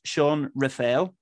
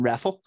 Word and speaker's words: Raphael: Sean Raphael, Richard assures me, is the correct pronunciation, rather than Raffle, which sounds more like a sweet.